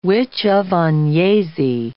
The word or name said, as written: witch of Agnesi